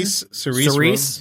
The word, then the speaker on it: Saoirse: Saoirse.